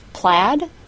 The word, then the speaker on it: plaid: Said, S-A-I-D, doesn't rhyme with paid, P-A-I-D. It rhymes with bed, B-E-D. Said, bed. And plaid, P-L-A-I-D, doesn't rhyme with paid either. It rhymes with sad. Plaid, sad.